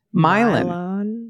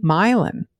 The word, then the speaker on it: Milan: Indiana also names of great European cultural centers like Milan, pronounced with a very long I sound. Milan. – Milan.